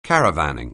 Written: caravanning

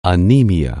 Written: anemia

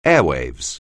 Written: airwaves

